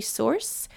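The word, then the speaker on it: Sorce: In the meantime, if you want to see more of my content, then check me out on YouTube, Kimberly Source. That's S-O-R-C-E.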